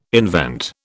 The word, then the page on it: invent: British English